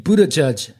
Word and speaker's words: Buttigieg: Jetzt habe ich geguckt, wie es die Amis aussprechen. Pete Buttigieg.